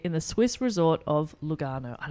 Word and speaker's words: Lugano: It's Lugano. – Lugano. Lugano. – Yeah, perfect. – With an Italian accent. That's how you say it.